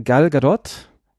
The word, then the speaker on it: Gal Gadot: Ja, unsere Protagonistin, gespielt von Gal Gadot, so spricht man sie übrigens, ist Rachel Stone und sie ist Teil einer MI6, ist das, oder?